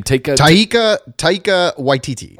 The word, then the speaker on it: Waititi: Taika Waititi.